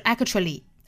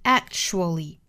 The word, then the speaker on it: actually: 不要把它爆破出来啊不是 actually 而是憋那么一下 actually